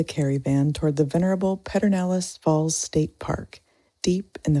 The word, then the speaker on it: Pedernales: So, for the Texans listening in, that's Pedernales.